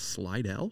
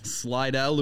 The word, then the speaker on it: Slidell: This week's guest hails all the way from, how do I say this, Slidell? – Yes. – Slidell, Louisiana, but now calls Whistler, British Columbia home.